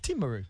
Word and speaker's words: Timaru: Lovely pronunciation too, Dan. Timaru.